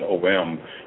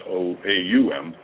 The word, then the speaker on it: Om: And then that sound, that OM, you know, that we pronounce as OM, is actually sounds OAUM.